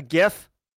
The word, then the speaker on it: GIF: A hard G, a GIF.